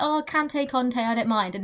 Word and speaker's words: Conte: What about Kante? How right, do you pronounce so... – Kante? – Conte.